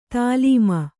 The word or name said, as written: ತಾಲೀಮ